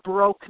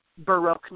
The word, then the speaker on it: Baroque: And literally, I didn't even know what Baroque music was, but I guess I had broke on the brain because I was broke. And so I said it wrong, but she handed me, you know, Mozart and Handel, and she hands me tapes.